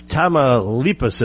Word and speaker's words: Tamaulipas: Victor Amandirez will have to tell me if I pronounced that correctly. Well, on point for Victor. It's Tamaulipas, it looks like.